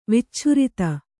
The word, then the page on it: ವಿಚ್ಛುರಿತ: Kannada